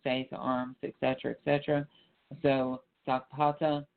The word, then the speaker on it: Sakpata: Um, uh, Sakpata is spelled uh, S A K P A T A. Sakpata.